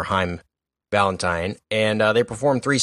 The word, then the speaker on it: Haim: If you ever want to, it's pronounced Haim, but people think they're called Haim or know them as Haim.